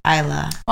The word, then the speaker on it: Isla: Oh, yeah, like, that's actually Spanish Isla. – Well, I know an Irish Isla. – Do you? – Because they don't pronounce the S. Isla.